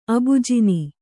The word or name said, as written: ಅಬುಜಿನಿ